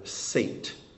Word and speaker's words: Seth: His son's name is Seth, or Sate is how it would be pronounced.